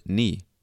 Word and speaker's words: knee: And we spell it K N-E-E. And it was around this time that Middle English was becoming Modern English that people stopped pronouncing the K in the word knee. So the K is now silent. It's still in the word. We still write it. We just don't pronounce it.